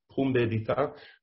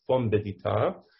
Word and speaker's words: Pumbedita: It's normally pronounced pumbedita. My father used to pronounce it fombedita, at the mouth. Literally, it's fombedita.